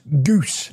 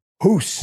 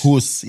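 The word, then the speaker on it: Guus: We've alienated all of our Dutch listeners and Dutch viewers because I was pronouncing Mr. Hiddink as goose. – Goose, yeah. Hiddink. Apparently, the Dutch tell me it's actually hoos. – Hoos, yeah.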